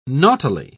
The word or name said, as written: naughtily